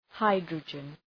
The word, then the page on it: hydrogen: {‘haıdrədʒən}